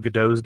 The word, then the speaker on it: Gadot: That is the correct or incorrect? – Gadot. – Yeah, it's correct.